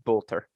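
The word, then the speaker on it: Boulter: I have, and I'm going to butcher the pronunciation, Voltaire beating Pliskova, unfortunately, even despite the fact that I had... – You know, she's British. It's exactly like it looks. It is just Bolter.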